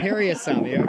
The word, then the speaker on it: Parasamy: Am I pronouncing that correctly? – Parasamy. – Okay, accent on the first syllable.